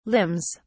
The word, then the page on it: limbs: British English